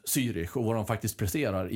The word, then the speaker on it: Zürich: Rögle mötte Zürich på hemmaplan. – Exemplariskt uttal. – Ja. Fem plus. Det sitter sen tsar. Följer med där till Zürich, ja. Om det nu är så man säger.